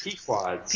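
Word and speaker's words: Pequod's: You can go to prison knowing the glory that is Pequids. Is that how you say it? Pequods? – Pequods.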